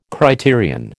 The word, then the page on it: criterion: American English